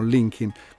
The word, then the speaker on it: Linkin: Abbiamo riascoltato appunto il Linkin Park, dove Linkin, non so se lo sapete, è la contrazione di Lincoln, cioè è Lincoln scritto come si pronuncia, perché il parco in questione è il noi in Italia diremmo il Lincoln Park, ma loro dicono Linkin.